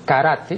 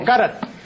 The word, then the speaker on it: karate: Eh, eh, aquí yo más de una vez he dicho que la pronunciación de lo que aquí equivocadamente pronuncian karate no es karate, sino karate.